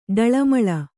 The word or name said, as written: ಡಳಮಳ